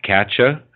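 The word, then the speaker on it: Katje: And Katya, and that Ch at the end is spelled J-E, because that's how Dutch, that's a diminutive in Dutch.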